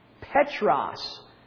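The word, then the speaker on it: Petros (Πέτρος): It is pi, epsilon, tau, rho, omicron, sigma. And it is pronounced Petros.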